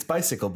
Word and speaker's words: Bisikle: it's bicycle, but it's spelled B-I-S-I-K-L-E. And it uses a little, it's not a marble, but it's like a little plastic ball with sand in it.